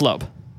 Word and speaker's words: Loab: L-O-A-B? – L-O-A-B, yeah. I've seen other people like pronounce it Loab, and I think that's wrong. – Yeah. – That feels wrong. – Yeah. – It's Loeb.